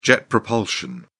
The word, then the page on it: jet propulsion: British English